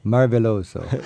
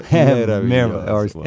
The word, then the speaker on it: Meraviglioso: How do you say stupendous in Italian? – Meraviglioso. – Marveloso. – Meraviglioso.